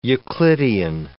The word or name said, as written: euclidean